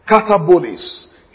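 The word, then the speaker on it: katabolēs: A-P-O. From can mean since. A-P-O, apo. Katabolis. K-A-T-A-B-O-L-E-S. That's the word foundation. K-A-T-A-B-O-L-E-S. Then cosmos. K-O-S-M-O-U or cosmos, K-O-S-M-O-S, the world.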